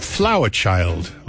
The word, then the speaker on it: FLWRCHYLD: Then we heard Flower Child, although it's spelled F-L-W-R-C-H-Y-L-D.